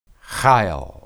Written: hayil

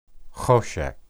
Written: hoshek

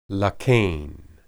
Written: laken

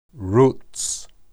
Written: ruts

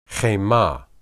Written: hemah

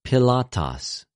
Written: Πιλατος

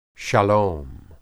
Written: shalom

